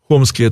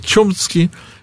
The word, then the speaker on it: Хомский: Хомский — это его русское произношение, на самом деле в Америке его называют Чомский, и он происходит из Российской империи, точнее, его отец был Российской империей, а Хомский родился в Америке.